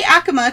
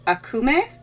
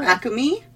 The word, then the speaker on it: Acoma: And I say Acoma because that's what a lot of people say, but apparently it's technically pronounced Akume or Akumi, either way.